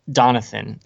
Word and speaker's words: Donathan: He struck me, Zeke was outgoing, but he wasn't as outgoing as Donathan, is how you pronounce it, correct? – I think so. It's just like Jonathan with a D. – Jonathan, yeah. – Yeah, Donathan struck me as very,